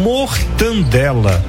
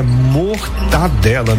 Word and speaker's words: mortadela: Olha, mortandela. Não é mortandela, gente, é mortadela. Não tem esse N aí, não. Viu? Não é mortandela, não. É mortadela. Tira esse N.